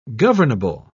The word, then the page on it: governable: British English